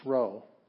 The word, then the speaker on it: rho: It's rho. And rho is in her without the E.